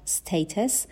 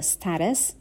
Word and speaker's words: status: status یا status تلفظ امریکنش هست